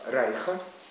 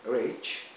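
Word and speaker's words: Reich: Если вы слышали сочинение, например, Стива Райха, иногда его произносят как Стив Рейч, Райх как-то более принято у нас.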